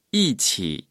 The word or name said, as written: 一起